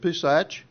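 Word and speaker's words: Pesa (פסח): Pesach. Am I pronouncing that correct, Pesach? How do you pronounce Pesach? The Feast of Pesach, is that? Okay, thank you. Pesa, all right.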